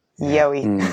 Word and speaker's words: gheobhaidh: Oh yeah, yo-y. So nice. – Because I love how it's spelt versus how it sounds. Yeah, you know, G H E O B H A I D H. Yoey.